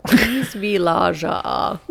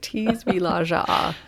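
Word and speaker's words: Tisvildeleje: Tisvillaja. They spelled it out phonetically. Tisvillaja. Which sounds remarkably close to P wants to play in Danish.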